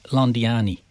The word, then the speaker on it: Londiani: Did I pronounce that right, Londiani? – You did, absolutely perfectly right.